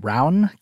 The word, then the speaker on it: Rowan: Side note, it's actually pronounced Rowan. Classic out-of-town reporter mistake.